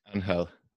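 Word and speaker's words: Ángel: Angel.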